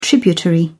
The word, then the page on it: tributary: British English